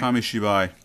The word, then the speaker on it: Kamishibai: And he was originally introduced, made his debut in Paper Theater, or Kamebashi. Am I pronouncing it right? Once again, people, I'm sorry. – Kamishibai, yes, thank you.